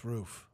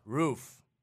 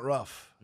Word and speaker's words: roof: Yeah, it's roof. – Roof. – It's not rough. – No, it's not rough. – We see it. Is it pronounced roof or rough? Why do Americans pronounce the word roof like a dog's bark? No. Only some Americans pronounce the word this way, far fewer than those who use the roof pronunciation. It isn't actually rough. It's closer to the sound in foot, but some people hear it that way.